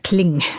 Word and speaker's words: Klein: Einer, der den Klang im Namen trägt, wobei man das, weil er Franzose war, nicht so unbedingt gerade merkt, weil Klein ausgesprochen, Mr. Kling sozusagen, Henri Adrien Louis Klein.